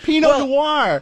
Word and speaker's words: Pinot Noir: We had a good talk there, totally off cuff about I love the way you just said Pinot Noir. – Was that right? – No, you didn't say it right at all. – Oh, God, help me out. – Pinot Noir. Pinot Noir. – Noir. – Yeah. – Noir. – It's French.